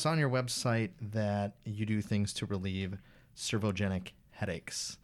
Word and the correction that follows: cervicogenic: Did I say that right? Servogenic? – Yeah. – What is a servogenic headache? – Cervicogenic headache is basically a headache that's induced by something that has to do with the neck.